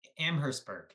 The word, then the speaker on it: Amherstburg: Amherstburg. I might be pronouncing it incorrectly. – Amherstburg. Yeah. – Amherstburg. Thank you.